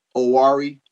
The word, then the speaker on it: Oware: But, you know, You know, my book is called Owari Mosaic, O-W-A-R-E. It kind of is pronounced like O-H-W-A-R-E-E-E, Owari Mosaic.